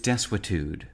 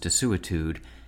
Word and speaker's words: desuetude: Today's word is desuetude, sometimes pronounced desuetude, and spelled D-E-S-U-E-T-U-D-E.